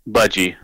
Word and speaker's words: Budgie: And Breadfan was originally by how do you say Boogie? Budgie. Budgie. I never heard of Budgie.